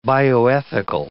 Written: bioethical

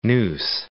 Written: Neuse

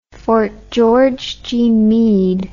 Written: fort george g meade

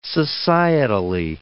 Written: societally

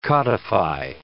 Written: codify

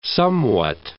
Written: somewhat

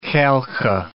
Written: Khalkha

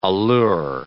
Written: allure

